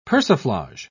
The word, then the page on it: persiflage: British English